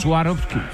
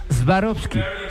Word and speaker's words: Swarovski: Se llama Swarovski. – Swarovski. – No se dice Swarovski. ¿se dice? ¿Cómo? Swarovski. Swarovski se dice.